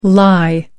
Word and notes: lye: American English

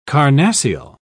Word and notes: carnassial: British English

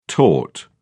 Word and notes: tort: British English

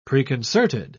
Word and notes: preconcerted: British English

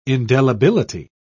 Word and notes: indelibility: British English

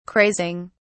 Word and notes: crazing: British English

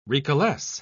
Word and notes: recalesce: British English

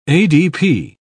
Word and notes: adp: British English

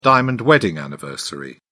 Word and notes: diamond wedding anniversary: British English

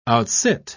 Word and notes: outsit: British English

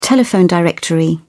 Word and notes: telephone directory: British English

